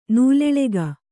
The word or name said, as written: ನೂಲೆಳೆಗ